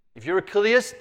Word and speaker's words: Chiliast: What is widely known about Papias is that he was an outspoken Kiliast. By the way, that is what we are as a church. We are Kiliasts. That's from the Greek word. You spell it C-H-I-L-I-A-S-T.